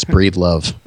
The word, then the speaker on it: Breedlove: Yeah, it's 100% how it's spelled, which is kind of awkward to say, but yeah, it's Breedlove.